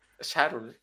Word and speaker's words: Saturn: Sarun. Es como pronunciación, pronunciación inglesa así de Birmingham un poquito.